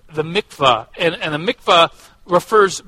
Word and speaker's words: mikvah: Um, it's called the the that I want to pronounce it correctly. The the mitz, mikvah. Yes, thank you.